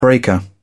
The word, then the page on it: breaker: British English